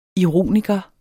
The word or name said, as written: ironiker